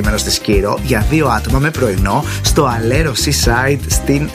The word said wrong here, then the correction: Αλερό: Στο Αλερό Seaside στη Σκύρο, για να το πω και εντελώς σωστά με προφορά. Αλερό, Αλερό Seaside Σκύρος.